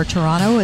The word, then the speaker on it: Toronto: Welcome back inside Rogers Center here in Toronto, Canada, or Toronto, as they say. They do not pronounce the second T. – Yeah, I try and abide by that. – They pronounce the first one.